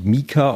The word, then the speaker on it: Meeker: Also wenn man mal mit den Sommerhimbeeren anfängt, die Sorte Meka, hast, oder also die schreibt sich M-E-E-K-E-R, wird glaube ich Mika ausgesprochen.